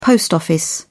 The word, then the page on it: post office: British English